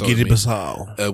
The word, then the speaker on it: Guinea-Bissau: it's Guinea-Bissau, would discontinue the use of the CFA franc in favor of the new common currency.